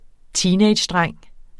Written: TEENAGEDRENGE

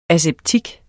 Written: aseptik

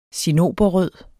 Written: cinnoberrød

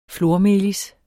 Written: flormelis

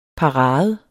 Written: parade

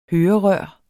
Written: hørerør

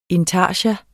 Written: intarsia

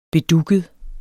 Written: bedugget

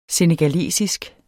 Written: senegalesisk

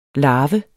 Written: LARVERNE

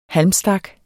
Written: halmstak

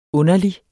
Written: underlig

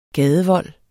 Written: gadevold

